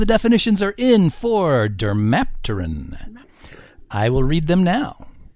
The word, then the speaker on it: dermapteran: The word for round two is dermapteran. Dermapteran. That is spelled D-E-R-M-A-P-T-E-R-A-N.